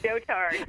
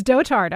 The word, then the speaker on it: dotard: I don't know how to pronounce that. – I looked it up, dotard. – It's dotard.